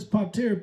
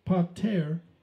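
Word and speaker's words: pater (πατήρ): Now, a father in the Greek, the word is pater, P-A-T-A-Y-R is how it's enunciated, pronounced pater, all right?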